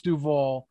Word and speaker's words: Duval: It's called Duval, not Duvalians. All right. So one thing before we leave here, just had to say that. Remember one thing. – Yeah, just remember one thing. Say one thing. You know what? It's spelled it's pronounced Duval.